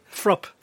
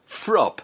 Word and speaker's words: Fruupp: Frupp. – Frupp. – Yes. – Spelt? – F-R-U-U-P-P.